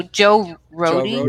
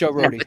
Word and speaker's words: Rohde: So Joe Roadie, right? – You don't say Joe Road, right? – No, it's Joe Rohde. – Yeah, Rohde.